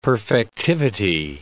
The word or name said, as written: perfectivity